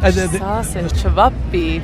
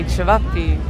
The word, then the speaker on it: Čevapi: Čevapi. Čevapi. – Čevapi. – There you go. – Čevapi.